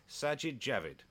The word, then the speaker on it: Sajid Javid: no, this one I do know because the part of my life as a broadcaster, I'm sure I've managed to say his name wrong so many times just because you put the J in the wrong place, whatever. Sajid Javid. – That is correct and very well pronounced as well. – Slowed down for you. You nailed it that time. Just enjoy saying it as well. It's a double J name.